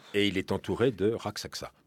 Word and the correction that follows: Rakshasa: de Rakshasa.